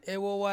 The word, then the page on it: wowa: Ansus